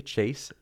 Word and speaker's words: Chase: Instagram's probably the one I'm more active on, and that's M as in Mary, Chase, like the verb, McCown.